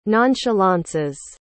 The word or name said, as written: nonchalances